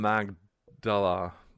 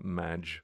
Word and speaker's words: Magdala: Read about Miriam from Magdala. And that line over the G is a hard G, not a soft G. It's not Magdala, it's Magdala.